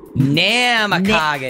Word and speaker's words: Namekagon: Namekagon.